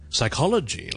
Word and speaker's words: psychology: OK，咁亦都有一個 silent P 嘅 psychology 啦，right？psychology 啦，心理學啦，psy c h o l o g y，同埋我哋聖經其中一本書叫 Psalm，p s a l m，p s a l m，咁啊詩篇，咁啊亦都係咧來自古希臘文，以前啊好耐之前啦，咁個 P 音亦都係唔讀嘅，咁就唔好讀成 P 三咁啦。